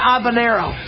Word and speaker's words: habanero: And habanero. Habanero, no H.